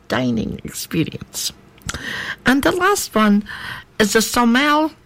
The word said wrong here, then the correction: sommelier: No, that's not how you say it. Sommelier. That's what it is.